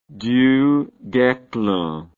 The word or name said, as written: Du Guesclin